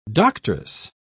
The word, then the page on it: doctress: British English